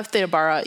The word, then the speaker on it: Theda Bara: I think is how you say Theda Bara, yeah.